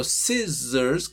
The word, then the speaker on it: scissors: Por ejemplo, class es con s, pero scissors, que es ss, es con z.